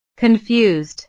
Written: confused

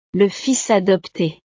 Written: le fils adopte

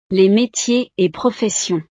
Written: les metiers et professions

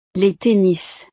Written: les tennis

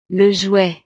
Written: le jouet

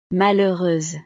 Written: malheureuse

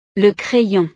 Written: le crayon

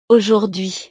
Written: aujourdhui

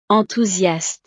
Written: enthousiaste

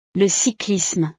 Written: le cyclisme

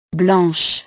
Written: blanche

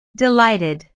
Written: delighted

